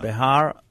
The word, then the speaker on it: Behar: Behar and Bechukotai.